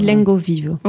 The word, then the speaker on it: Lengaviva: Ça s'écrit Lengaviva, voilà, mais ça se dit Lengovivo.